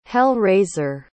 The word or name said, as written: hell-raiser